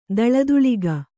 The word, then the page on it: ಥಳಥಳಿಕೆ: kannada